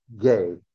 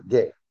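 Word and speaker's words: gē: The word earth here is the Greek word ge, gamma eta, ge, G-E, ge, long E.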